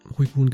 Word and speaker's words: Uiguren: Uiguren. Ich hoffe, ich habe es richtig ausgesprochen. – Uiguren, genau.